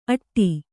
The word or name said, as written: ಅಟ್ಟಿ